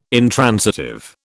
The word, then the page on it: intransitive: British English